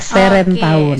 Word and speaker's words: Seren Tahun: Bacanya Seren ya Teh? – Iya Seren Tahun.